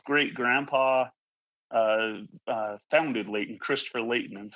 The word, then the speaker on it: Layton: And we found in his journal that he actually bemoaned someone pronouncing the T, because he was from England and a part of England where they didn't pronounce the T's, and it's Layton.